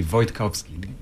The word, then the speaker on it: Wojtkowski: So it's Renek Wojtkowski. In Polish, I'm originally from Poland. It would be Wojtkowski.